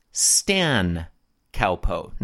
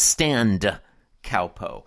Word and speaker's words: Stan Cowpo: It is widely agreed that the short form of standard countertop position is Stan Cowpo, not Stand Cowpo. I don't know why he insists on pronouncing it the way he does.